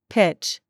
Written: CHURCH

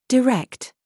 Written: RAW

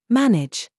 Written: JUDGE

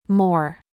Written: mum

